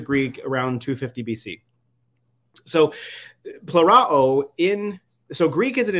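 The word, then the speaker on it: plerao (πληρόω): And the way it was pronounced is actually plerao. So the omega is a long O, the omicron is a short O, so plerao.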